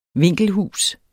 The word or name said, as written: vinkelhus